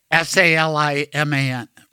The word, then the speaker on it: Saliman: S A L I M A N.